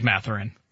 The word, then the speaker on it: Mathurin: Kristaps Porzingis has his hand up play is questionable, but Porzingis gets called for a foul, and the uh, Indiana player, Danny, who was it? Mathurin, right? Mathurin? – Bennedict Mathurin. – Bennedict Mathurin, excuse me, my, my pronunciation mistake.